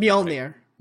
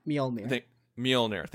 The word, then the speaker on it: Mjolnir: And also, like, one of my favorite moments is when Cap nudges Mjolnir. I'm probably not pronouncing Mjolnir. – Mjolnir. Thank you. I always pronounce the J, and I know I shouldn't.